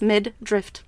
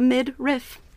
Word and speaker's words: midriff: I thought it was mid-drift. – Me too. – No, it's a mid-riff.